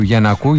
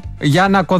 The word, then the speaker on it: Γιαννάκο: Μανιάνα μου, ποτέ δεν θα μάθω που τονίζεται το επώνυμο του κυρίου Γιαννάκου. Γιαννάκος μου λέει Μανιάνα λέγεται. Το λέμε Γιαννάκο, Γιαννάκο. Γιάννακο δεν τον έχουμε πει ποτέ, αλλά Γιαννάκο, Γιαννάκο τον λέμε.